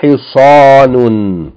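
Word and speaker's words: hi sa nun (حصان): It is pronounced, the word for horse is pronounced ha with kasra, sad with fatha elongated by aleph, noon with tanween, short vowel, long vowel, tanween, hi sa nun, hi sa nun.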